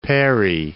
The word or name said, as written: Parry Islands